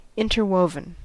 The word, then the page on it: interwoven: American English